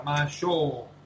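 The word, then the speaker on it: Mashou (ましょう): Cuando yo escribo O-U es porque estoy refiriendo a una O larga, Mashou.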